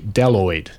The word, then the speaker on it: bdelloid: And for those of you listening, the B is silent. It's actually written B-D-E-L-L-O-I-D, Daloid, apparently. So it's got a silent B at the start of the word.